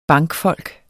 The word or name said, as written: bankfolk